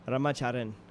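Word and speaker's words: Ramacharan: Which is interesting because what I know about Hindi, but just what I've heard, is it's Rama Charon or would it be Rama Charon? – You know, it really would be pronounced Rama Charon.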